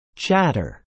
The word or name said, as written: chatter